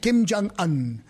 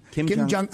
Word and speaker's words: Kim Jong-un: How do you say that? Right. – Kim Jong-un. – Kim Jong-un.